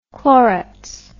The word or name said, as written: Clorets